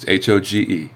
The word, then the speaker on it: Hoge: There's no D's and no U's in Hoge. It's Hoge. It's Hog with an E. Long O.